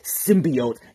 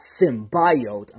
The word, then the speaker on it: symbiote: And by the way, it's pronounced symbiote, not symbiote.